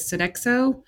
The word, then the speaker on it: Sodexo: So number 23, I hope you pronounce this correctly, is Sodexo.